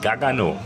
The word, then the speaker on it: Gargano: In Gagano, wie wir Hessen sagen. – Ach so, ohne R. – Es gibt kein R im Hessischen. – Also dann schreibt man Gargano im Hessischen mit 2G. Nee, mit 3. Gargano.